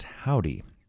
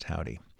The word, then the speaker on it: Hovvdy: They call themselves Indie Pop, and the name of the band is pronounced Howdy, but it's spelled with two V's instead of a W, so go figure. H-O-V-V-D-Y, pronounced Howdy.